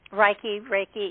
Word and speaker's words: Reiki: Called, that's what's called the Reiki master, or Reiki? I'm not mispronouncing that. – Reiki, yeah. Reiki is energy work, sure.